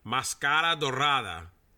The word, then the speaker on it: Mascara Dorada: Did I say that right? Mascara Dorada. That better? – Yep, there we go.